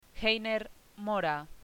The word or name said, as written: Heiner Mora